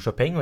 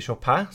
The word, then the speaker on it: Chopin: Ja, dagens tema er Chopin, og dere har jo hørt det allerede flere ganger nå, at vi uttaler det som Chopin og ikke Chopin, som mange uttaler det. Det er litt fordi han er jo polsk og ikke fransk, så jeg syns det er mer naturlig å uttale det på den måten.